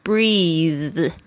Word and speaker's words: breathe: B-R-E-A-T-H. 就是这个这个声音。那它的动词是 breathe. 所以大家特别注意一下，它发音会不太一样哦。 Breath 是名词， breathe 是动词。 And relax, R-E-L-A-X, 就是呢放松。